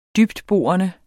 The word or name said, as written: dybtborende